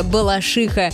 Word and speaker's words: Балашиха: Ну, конечно же, наша любимая Балашиха. Ударение в этом слове правильно ставить на последний слог.